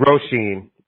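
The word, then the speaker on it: Róisín: Wait, how do you pronounce it? Roisin? I don't know. It's Irish. – Roisin. – Roisin? Okay, Roisin. – Roisin. Yeah, Roisin. Okay.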